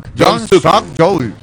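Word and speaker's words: Yoon Suk Yeol: Jong Suk Jeol. Okay. – No sé si se pronuncia. Jong Song. Jong Suk Yeol.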